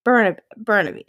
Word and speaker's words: Burnaby: She and her family owned a Chinese restaurant in Bumblebee, British Columbia. I don't know if I'm saying that right. Burnaby? – How do you spell it? – Burnaby. It has to be Burnaby. – B-U-R-N-A-B-Y. I think so.